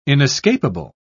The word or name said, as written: inescapable